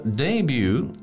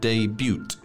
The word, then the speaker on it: debut: And remember, debut, there is a T, and it is a silent T. So you don't say debut, it's debut.